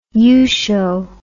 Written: yusho

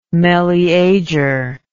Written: meleager

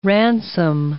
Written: ransom

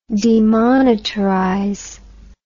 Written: demonetarization

